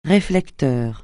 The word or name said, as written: réflecteur